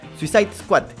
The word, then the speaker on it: Suicide Squad: Primera cuestión: Suicide Squad. Lo pronuncié como el culo. Hagámoslo de nuevo: Suicide Squad.